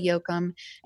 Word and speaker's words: Jochim: So YouTube, and my name is Cordila Yoakum, and you spell that Cordila. The last name is Jochim.